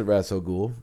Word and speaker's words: Ra's al Ghul: So, but I do pronounce it Ra's al Ghul.